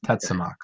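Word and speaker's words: Tetzimoc: And yeah, so the black dinosaur, I just want to clarify its name, it's Tetsimak, is that right? – I mean, I can't speak with 100% enunciation on these, but I would believe it's pronounced Tetsamok.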